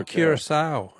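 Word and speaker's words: Curaçao: I don't know if I'm pronouncing it right, but I thought it was Curacao. – Oh, Curacao, yeah. No, that's very good. I'm sure you're pronouncing it correctly, but that's not...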